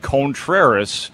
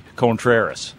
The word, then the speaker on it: Contreras: But Wilson Contreras, that is the proper way to pronounce it, yeah. And he is officially Contreras.